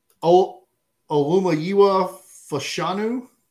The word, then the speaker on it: Olumuyiwa Fashanu: I'm going to butcher this one, but it's Penn State third-year offensive tackle Olumuyiwa Fashanu. Close enough. – Yeah, I think it's pretty good.